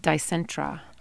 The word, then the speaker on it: Dicentra: Its Latin name is D-I-C-E-N-T-R-A. How do you pronounce that? – Dicentra. – Actually, it's Dicentra. All these years, I did not know that.